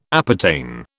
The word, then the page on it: appertain: British English